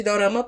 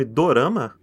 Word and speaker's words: dorama: Inclusive, você sabe da onde vem o nome dorama? – É, origem? dorama japonês. – São produções japonesas, né? Dorama é como um japonês pronuncia a palavra drama.